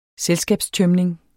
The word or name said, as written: selskabstømning